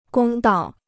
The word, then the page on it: 公道: gōng dao